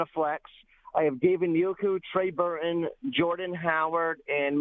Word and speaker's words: Njoku: Njoku. Njoku, everybody. – Pretend there's a U and an H after the N. Njoku.